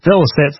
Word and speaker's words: Velocet: I was going to listen to, do I say it, Velocet? – Velocet's the correct pronunciation.